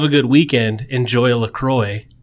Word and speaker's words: LaCroix: LaCroix? LaCroix? How do you say it? – I say LaCroix.